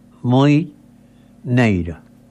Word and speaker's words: muñeira: A muñeira, pronunciación en gallego, a muñeira.